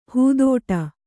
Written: ಹಿದೌಠ

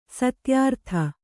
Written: ಸತ್ಯರತ

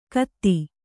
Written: ಖತಿ

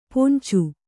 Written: ಪೊಂಚು

